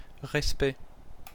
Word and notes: respect: France (Paris); [ʁɛs.pɛ]

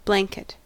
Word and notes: blanket: /ˈblæŋ.kɪt/

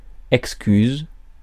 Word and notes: excuse: American English

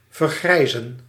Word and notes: age: /eɪdʒ/

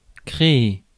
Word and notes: produce: American English

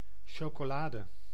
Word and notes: chocolade: /ˌʃo.ko.ˈla.də/

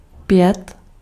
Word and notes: chanter: /ʃɑ̃.te/; France

